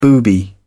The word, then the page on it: booby: British English